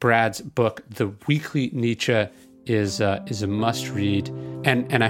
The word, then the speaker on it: Nietzsche: You said it correctly. It's Nietzsche.